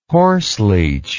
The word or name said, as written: horseleech